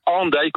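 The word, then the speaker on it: Hendaye: Et d'ailleurs, Andaille s'écrit aujourd'hui H-E-N, donc peut-être qu'en français on dirait plus facilement Andaille. – Ah oui. – Mais c'est bien Andaille qu'on dit. On a bien conservé le son ou la prononciation qui était celle de l'orthographe du XVIe siècle.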